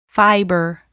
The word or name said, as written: fiber